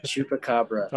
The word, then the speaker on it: chupacabra: Also, can you pronounce the name of the mythical beast from Mexico for me? – Chupacabra. – Okay. Chupacabra stole some points from you.